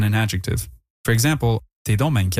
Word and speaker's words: donc: You'll also hear bain used with donc, the word for therefore, so, or thus, pronounced donc, and placed before bain for additional emphasis.